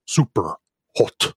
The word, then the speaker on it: Superhot: Superhot.